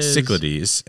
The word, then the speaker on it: Cyclades: 61 is Cyclades. – Or Cyclades, as it is correctly called.